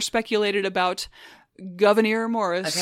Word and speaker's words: Gouverneur: Again, his name is derived from his mother's Huguenot French Protestant name, surname, pronounced Gouverneur.